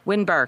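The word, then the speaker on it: Windber: He's one of the owners of the Grand Midway Hotel in Winbar, Pennsylvania. Winbar, excuse me. Say it wrong like 12 times.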